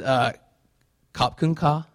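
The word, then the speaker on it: Kop Kun Ka (ขอบคุณค่ะ): Laura, is it Kop Kun Ka? Is that how you say it? Yes, that's what I'm talking about.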